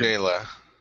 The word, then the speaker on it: Jaylah: Jaina. J-A-Y-L-A-H, okay.